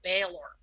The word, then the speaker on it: Baelor: It's spelled B-A-E-L-O-R. And I'm listening to the word in my head and I'm like, that's got the word bale in it, B-A-L-E, the sound of the word bale, Baylor.